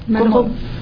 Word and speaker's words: Malraux: Malraux.